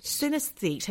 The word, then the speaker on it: synesthete: S-Y-N-E-S-T-H-E-T-E. – You got it. That's the one. I think that is, what, how do you say it? – Synesthete. Like synergy.